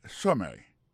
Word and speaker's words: summary: Par exemple, sous premier mot et summary, pour question de prononciation, accent tombé sous premier syllabe là, summary.